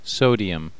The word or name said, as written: sodium